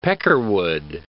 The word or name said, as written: peckerwood